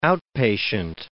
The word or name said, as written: outpatient